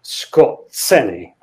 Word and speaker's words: Skorzeny: It's Skorzeny. I think Skorzeny is how most people, probably most Germans, pronounced it when not educated. So Skorzeny. Anyway, eventually he finds himself, he's hauled into a room with five others, I think it is.